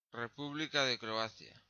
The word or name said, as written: República de Croacia